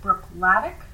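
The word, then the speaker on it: Bruichladdich: Now, this distillery, it's not spelled like you'd figure. I'm pronouncing it Brookladic. But it's a Scottish word, so there are a lot of extra letters in there.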